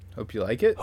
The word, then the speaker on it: Hopeulikit: I'll spell it out and then I'll pronounce it. It's Hopeulikit, Georgia. – Hope you like it.